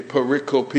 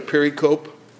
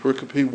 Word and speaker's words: pericope: Okay, pericope, it's quadrisyllabic. It looks like pericope, but it's pronounced pericope.